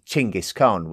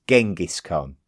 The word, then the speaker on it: Chinggis Khan: A man called Temujin had successfully unified the Mongol tribes under one rule, and he assumed the name Chinggis Khan, which has been traditionally pronounced in the English-speaking world as Genghis Khan.